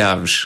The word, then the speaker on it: Aves: Para mim é sempre o Aves. – É o Aves. Em pronúncia é Aves.